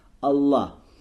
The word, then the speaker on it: Аллах: Аль-Илях, но вот это Илях, Аль-Илях, оно удалено, не произносится. Почему? Для облегчения. Аллах. Не Аль-Илях говорится, а говорится как Аллах.